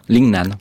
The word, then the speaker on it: Lingnan: prononce comment ? – Lingnan. – Lingnan, voilà.